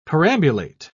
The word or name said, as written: perambulate